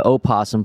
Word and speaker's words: opossum: Opossum can be, can be pronounced with its first syllable either voiced or silent.